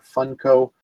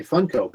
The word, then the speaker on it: Funko: I think it's either way you like, but I pronounce it Funko.